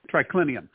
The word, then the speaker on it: triclinium: You know, when I look at a modern image of a triclium, what's the pronunciation? – Triclinium.